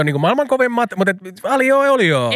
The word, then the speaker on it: aglio e olio: How do you say it? Aglio, meaning garlic, in Italian, e olio. Aglio e olio.